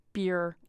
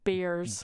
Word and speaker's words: Behr: It's spelled with a H. It's not beer. It's Bears. – B-E-H-R. – Yeah, it's Bear. – Weird. Bear.